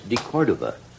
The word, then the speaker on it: De Cordova: All the biggies, Freddie DeCorvita. How do you say DeCorvita? Huh? You kept mispronouncing it all night at the party. De Cordova.